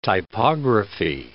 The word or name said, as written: typography